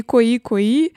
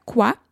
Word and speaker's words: quoi: Кстати, Куакуа это, как это название только не произносит, и кои кои, и куи куи, в общем, по-разному, но на самом деле это произносится ква, на французский манер, потому что это французское слово, которое переводится как «что».